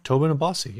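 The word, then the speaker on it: Tosin Abasi: Tobin Abasi.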